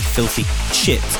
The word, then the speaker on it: Filthy Chit: Now, let me just make sure I'm pronouncing that properly. Filthy Chit. That's with a PH on Filthy and C-H-I-T, okay?